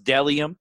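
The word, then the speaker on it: Bdellium: Dellium Clothing is pronounced Dellium, even though there's a B in the front.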